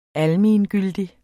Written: ALMENGYLDIG

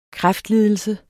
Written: kræftlidelse

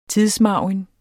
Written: tidsmargin

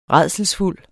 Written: rædselsfuld

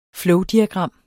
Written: flowdiagram

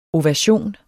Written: ovation

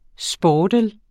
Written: sportel

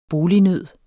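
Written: BOLIGNØD

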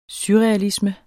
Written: surrealisme

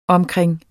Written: omkring

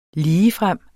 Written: ligefrem

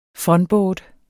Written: funboard

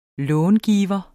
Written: långiver

